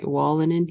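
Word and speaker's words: Wolanin: Chris, all Tyler's fault, did Christian Wallinen. What did I say that right? Wallinen? Yeah.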